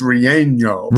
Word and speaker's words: relleno: And I'm never the one to correct pronunciation because I don't get it, but I believe it's relleno. – Relleno. Yeah, it doesn't have that little squiggly line on it.